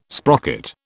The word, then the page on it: sprocket: British English